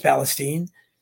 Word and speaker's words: Palestine: but I wasn't aware there was an East Palestine, pronounced Palestine, Ohio.